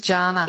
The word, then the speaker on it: Jana: It is Jana. – It is Jana. – Oh. Yes, I did grow up with Jana Vent on TV, but mine's a hard J, so thanks for checking.